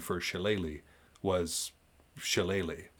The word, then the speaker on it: shillelagh: None of Irish is spelled like it sounds. Shillelagh is like shillelagh.